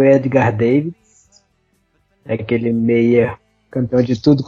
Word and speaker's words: Davids: É Davids. Não, eu nunca, nunca pesquisei, não. Eu tô perguntando aqui. – Sim, na pronúncia holandesa mesmo é Davids.